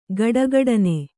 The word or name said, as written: ಖಡಖಡನೆ